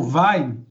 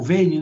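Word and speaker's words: Veyne: E, e tem um, o livro do, do Paul Vine, que, Paul Vane, né, que fala é como se escreve a história, né?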